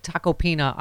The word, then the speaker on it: Tacopina: I know you saw uh, Tacopina on first time I've said his name right, on uh, Ari Melber's show.